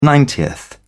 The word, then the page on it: ninetieth: British English